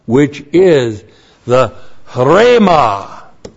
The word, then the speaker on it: Rhema: We have Rhema. That's the H breathing over the word. Rhema. H. R, long E, M, A.